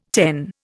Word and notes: ten: Indian

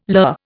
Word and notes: lur: Indian

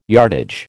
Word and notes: yardage: American English

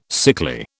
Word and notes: sickly: British English

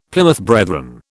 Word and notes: Plymouth Brethren: British English

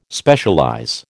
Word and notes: Specialize: American English

